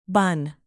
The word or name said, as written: bun